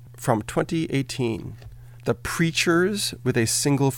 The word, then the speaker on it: Preatures: Coming up now, Yumi Zuma, The Preachers, spelled like creatures but with a P, and this one from Scottish band Travis, from their 2007 The Boy With No Name album.